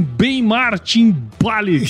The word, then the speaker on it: Ben Martin Balik: Estamos começando mais um episódio aqui do AgroResenha e nessa semana eu tô aqui com Ben Martin Bali.